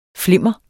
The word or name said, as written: flimmer